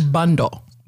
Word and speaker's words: Bandoh: B-A-N-D-O-H. It's actually bundle, but people can't say it. – Bundle. – Uh-huh. But people say Bando.